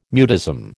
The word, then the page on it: mutism: American English